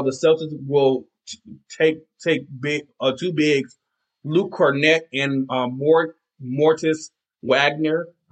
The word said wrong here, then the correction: Moritz Wagner: Yeah, let me try to say his name again. His name is, Moritz Wagner.